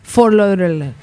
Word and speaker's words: Fort Lauderdale: Fort Lauderdale.